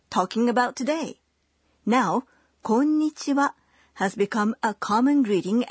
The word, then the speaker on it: こんにちは: It's the same as in the wa in こんにちは. – Hello. We pronounce it wa, but spell it ha.